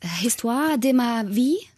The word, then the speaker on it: Histoire de ma vie: Histoire de ma vie, so richtig ausgesprochen, genau, ist der Originaltitel.